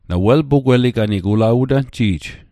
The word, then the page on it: pugweliganig: Mi'gmaq